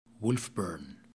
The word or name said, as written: wolfburn